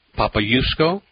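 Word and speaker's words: Popiełuszko: I'm talking to Gary Chartrand about the remarkable movie, 90-minute documentary on the life of Father Jerzy, and I'm going to try to pronounce it, Papayusko. – Is that close? – Yes. That is.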